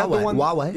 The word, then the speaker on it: Huawei: Huawei. – Is that how you pronounce it? – Yeah. – Huawei.